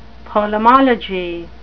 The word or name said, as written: polemology